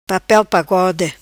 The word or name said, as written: Papel pagode